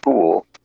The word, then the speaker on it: foul: The English spelling of it is F-O-U-L, but it's pronounced fool.